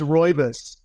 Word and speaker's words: rooibos: We've got caffeinated black teas and green teas, and we have non-caffeinated chamomile, and it's pronounced rooibos.